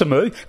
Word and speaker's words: Amoo: It's Chris Amoo.